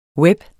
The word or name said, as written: web